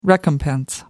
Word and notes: recompense: American English

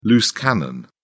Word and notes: loose cannon: British English

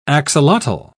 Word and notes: axolotl: British English

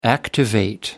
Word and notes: activate: American English

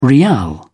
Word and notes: riyal: British English

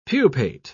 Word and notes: pupate: British English

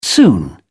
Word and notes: soon: British English

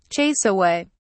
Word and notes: chase away: British English

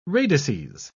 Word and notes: radices: British English

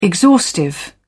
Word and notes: exhaustive: British English

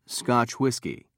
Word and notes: scotch whiskey: British English

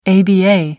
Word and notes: aba: American English

